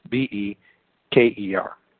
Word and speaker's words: Abu Bakr: Unlike the Goths, who burned up everything they conquered, Abu Bakr, the first caliph after Muhammad. And by the way, I'm saying his name wrong, but it's Abubeker.